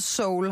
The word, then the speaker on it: Seoul: Er der, lige, der er lige et E i, som jamen, vi udtales Seoul, har du lært mig i dag.